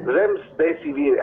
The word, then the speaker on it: Remdesivir: el antiviral Remdesivir, es medio difícil de pronunciación. – Remdesivir. – Remdesivir, ahí está.